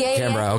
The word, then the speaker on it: Canberra: Canberra, okay.